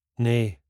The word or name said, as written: Nae